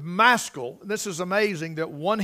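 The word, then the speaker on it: Maschil: And the CH is pronounced with a K, Maskil, Maskil.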